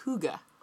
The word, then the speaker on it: hygge: It's not Finnish, it's Danish. I fucked up. And it's pronounced hygge.